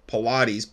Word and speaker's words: Paulides: That book is available on Can-Am, like Canadian America, Can-Am, missing website, called the HUPA, H-U-P-A Project. David Pilates, his last name is spelled P-A-U-L, like Paul, Pilates, P-A-U-L-I-D-E-S, and you can find him on YouTube,